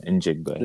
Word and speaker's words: Njigba: Jackson, Smith, and Njigba. I think that's how you pronounce it. Correct me if I'm wrong. – Njigba? Njigba, yeah. – Njigba. – You got it, though.